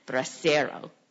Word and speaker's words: Bracero: Kim Hernandez told me how to say it, Bracero.